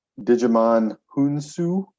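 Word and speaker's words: Djimon Hounsou: Jackson, Ben Mendelsohn, Digimon Hounsou. Am I saying that right? – Yeah, it sounds right.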